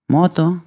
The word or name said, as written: batailler